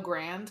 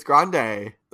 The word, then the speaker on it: Grande: I'm pretty sure it's pronounced Grand, not Grande, Ariana. – It's Grande. The Rio Grande.